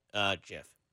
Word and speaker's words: GIF: It's pronounced Jif, not Gif. – All right, well, I still think it's Gif. – Yeah, well, it's not. – Yeah. – The owner says it's called Jif.